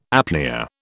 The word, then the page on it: apnoea: British English